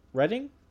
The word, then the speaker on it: Rettig: Redding. – R-E-T-T-I-G.